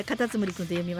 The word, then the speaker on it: カタツムリ君: はい、これはカタツムリ君と読みますよ。カギウじゃないですよ、はい、カタツムリ君ですね。